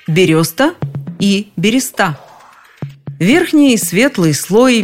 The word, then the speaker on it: береста: Многие настолько привыкли к бересте, что форму «береста» воспринимают в штыки. А зря. Вот, например, словарь ударений Агеенко и Зарвы этот вариант вообще приводят как единственный верный.